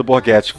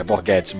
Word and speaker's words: Borghetti: Temos o Eduardo Borghetti como Cyborg. Sim, é, Edson, se você estiver ouvindo isso, sim, você estava certo na pronúncia. É Borghetti mesmo.